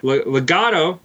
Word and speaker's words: Legado: Then our main event was Legado. I don't know if I said that right. Legado. There we go.